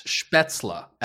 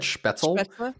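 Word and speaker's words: Spätzle: Spetzla, actually. I think because I think I said Spetzel, and then a bunch of people tweeted at me that it's Spetzla.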